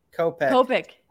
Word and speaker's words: Kopech: Kopech.